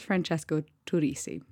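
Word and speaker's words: Francesco Turrisi: So right up top, I'm just going to say his name is Francesco Turisi, not what I said.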